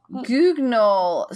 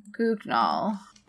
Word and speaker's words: Guignol: Guignol. So, okay. Guignol. – They're short plays. – Yes. So there was this thing in post-World War II, Grand Guignol. Le Théâtre du Grand Guignol.